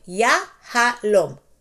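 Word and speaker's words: Yahalom (יהלום): It is Yahalom. Yahalom. Put together, Yahalom. And it's actually two syllables, that one is Yah, and then the other one is Halom.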